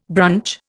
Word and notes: brunch: Indian

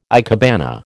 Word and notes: ikebana: American English

